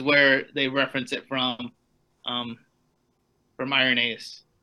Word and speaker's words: Irenaeus: I always pronounce his name wrong. – Irenaeus. – I think it's Irenaeus. – Irenaeus.